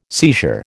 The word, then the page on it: seizure: American English